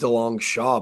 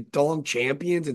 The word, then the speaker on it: DeLongchamps: It's actually pronounced DeLong Shaw, but who am I going to DeLong Champions? It's like been the greatest fantasy football name ever.